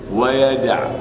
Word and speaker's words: يدع: هذه أربعة أفعال في القرآن يظن القارئ أن بعد العين واو وهي ليست كذلك، أو بعد حاء ويمحو الله واو ليست كذلك، ولكن محذوفة في الرأس فتحذف بالنطق، فأقول ويمح ويدع.